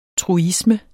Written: TRUISMEN

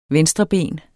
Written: venstreben

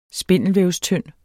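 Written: SPINDELVÆVSTYND

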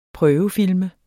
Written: prøvefilme

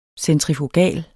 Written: centrifugal

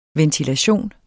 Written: ventilation

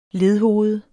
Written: ledhoved

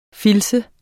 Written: filtse

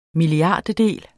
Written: milliardtedel

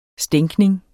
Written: stænkning

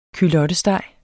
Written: culottesteg

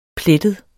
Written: plettet

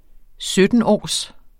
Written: syttenårs

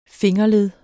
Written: fingerled

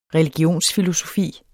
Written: religionsfilosofi

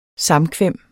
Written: samkvem

